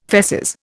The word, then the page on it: feces: Indian